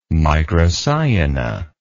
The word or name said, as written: microciona